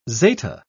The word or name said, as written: zeta